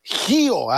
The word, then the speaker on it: Gio: Gio con J, Gio, haga usted de cuenta que es como se escribe el nombre de este hombre nacido en Tucson, Arizona.